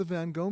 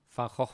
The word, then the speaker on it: Van Gogh: It is not the Van Gogh Museum. I'm sorry. I'm going to let my next guest pronounce it. It is the... – Van Gogh Museum.